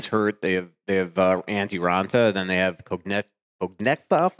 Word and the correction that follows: Kochetkov: How do you pronounce it? – Kochetkov. – There you go.